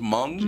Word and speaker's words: Hmong: They Americanized it to H-M-O-N-G. – Is it actually pronounced Hmong? – Hmong. Yes. Yeah. – But it's H-M-O-O-B.